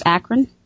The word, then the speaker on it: Akron: It's Akron.